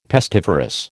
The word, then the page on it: pestiferous: American English